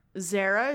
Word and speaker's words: Zara: Zara is supposed to have a rolled R.